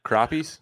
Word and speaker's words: crappie: Crappies? – Yeah, crappie. Yeah. That's the right way to say crappies.